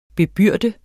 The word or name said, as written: bebyrde